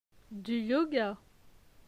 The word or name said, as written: Du yoga